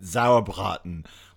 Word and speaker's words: Sauerbraten: Only if you learn to say it right. Sauerbraten. Listen, if you speak German, you have to imagine being very angry with a big sausage in your mouth.